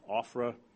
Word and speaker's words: Ophrah: Now, Ophrah, it's pronounced like it was O-F-F-R-A, Ophrah.